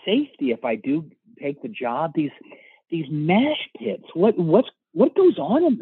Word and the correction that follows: mosh: They're called mash pits. – Mosh pits. – Spell it? – MOSH. – Oh, I thought it was mash. – It's not mash, it's mosh.